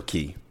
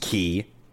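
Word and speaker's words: Cay: Green Turtle Key, to be specific. And let it be known that it's pronounced Key. It is not pronounced K. K is, you know, how the white people freaking pronounce it.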